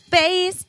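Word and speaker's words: Béis: Or you can use the promo code Busy, B-U-S-Y, at the checkout. You guys, go right now. Do it. Bass.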